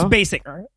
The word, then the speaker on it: Basinger: Oh, that's is it Basinger? I don't know. – It's Basinger, but yeah.